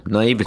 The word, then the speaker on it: naivety: they use games and kids' naivety. What is that word? – You said it right first time. Naivety.